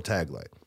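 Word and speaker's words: Tagle: And that is Cardinal, and now the first time we did this, I mispronounced his name. Ryan pronounced it right. I corrected him. Mm-hmm. Cardinal Taglay mm-hmm.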